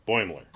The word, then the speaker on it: Boimler: Boimler.